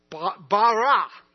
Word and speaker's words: bara: The first term, and the term that's used in Genesis 1:1, is the term bara. And you have to kind of roll your R's a little bit, and I can't do that very well.